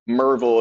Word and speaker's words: Maryville: Merville, if you're from there. They don't like you to pronounce every letter in that city name for some reason.